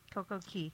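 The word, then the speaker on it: CocoCay: Coco Key. – Coco Key? – Key. – Coco Key. It's spelled K, pronounced Key.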